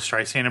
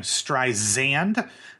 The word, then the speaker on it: Streisand: Currently, if you try it on your own, Siri is pronouncing her last name Streisand, not as Barbara says, with a soft S, like sand on the beach.